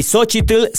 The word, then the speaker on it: Xóchitl: Y Xochitl sí lleva la TL. Para mí sí tiene que pronunciarse al final.